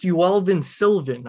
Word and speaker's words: Yoelvin Silvan: First, Yoelvin Silvan. I want to make sure I said that correctly. – Mm-hmm. Yep.